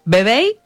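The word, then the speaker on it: Vevey: Actualmente está en Suiza, en la ciudad de Veyev, sobre el lago Lemán, en el cantón de Vaudou. ¿Lo dije bien? – Bebey y el cantón de Vaud.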